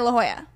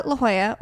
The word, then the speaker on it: La Jolla: So we went to La Jolla, or as Megan initially said, La Jolla. And I was like, it's not. I did. It's not pronounced like that.